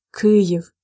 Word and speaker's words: Kyiv: In Ukraine, in Ukrainian, we say Kyiv, and we prefer to spell it in Latin as K Y I V, Kyiv.